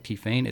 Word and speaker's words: Tiphaine: It's spelled T-I-P-H-A-I-N-E, kind of like Tiphaine. It's Tiphaine, T-I-P-H-A-I-N-E, Dixon, D-I-C-K-S-O-N.